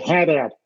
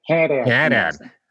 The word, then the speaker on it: Haddad: Haddad, and that's how they say it in Boston, right? – And they say Haddad. – Haddad.